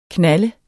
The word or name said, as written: knalde